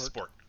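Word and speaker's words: sport (спорт): The first letter is pronounced S. The second letter is a pi, so it's pronounced P. The third letter is pronounced R. And the O and the T are pronounced the same. – Spurt. – But they're called different things. So it's sport.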